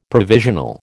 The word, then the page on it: provisional: American English